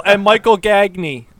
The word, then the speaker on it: Gagné: It's because of Simone Gagné, and growing up in the Philadelphia area, Simone Gagné of the Philadelphia Flyers. So we all know how to pronounce his name. – How to say that? – Because if it wasn't for that, we would have just destroyed this name.